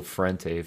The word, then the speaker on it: Frente: I'm like an old school Frente fan.